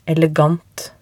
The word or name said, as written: elegant